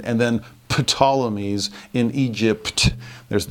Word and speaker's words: Ptolemies: And the two that stand out, they're called the Seleucids in the north and the Ptolemies, that's spelled with a PT, the Ptolemies in the south.